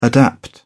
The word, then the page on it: adapt: British English